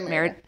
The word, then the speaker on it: Merida: It says, you remember Merida opening doors and the, I'm saying that right, right? – Yeah, Merida.